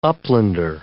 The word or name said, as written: uplander